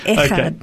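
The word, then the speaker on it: echad (אחד): and the word Ikad have I said that right? – Oh I've got to get more. Got, it needs that little in there. – echad.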